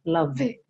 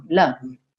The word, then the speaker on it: love: Sempre lembrando que love, não se pronuncia a letra E. Então, você fala love. Não, love. Para na letra V.